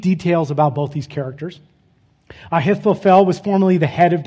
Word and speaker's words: Ahithophel: It's actually pronounced Ahithophel, but I'll save you guys the guttural because I have to say his name a lot.